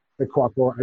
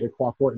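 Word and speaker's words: Ikwuakor: just with Coach AK Ikwakwor. I do believe, I'm pretty sure I'm the only AK Ikwakwor in the world.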